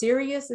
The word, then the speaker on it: Sirius: And that is pronounced Sirius. Is that correct? S-I-R-I-U-S.